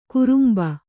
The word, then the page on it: ಕುರುಂಬ: kurumba